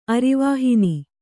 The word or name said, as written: ಅರಿವಾಹಿನಿ